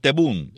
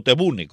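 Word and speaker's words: Tebboune: Se reúne en Argelia con el presidente Tebun, o Tebune, como le quieren pronunciar.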